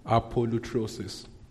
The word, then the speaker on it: apolutrosis: So we said redemption, you know, the Greek word is apolutrosis. A-P-O-L-U-T-R-O-S-I-S. Apolutrosis. All right? It's pronounced, interesting. Apolutrosis. Same thing. All right.